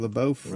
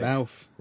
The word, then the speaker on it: LaBeouf: LaBeouf. – Right. – LaBeouf.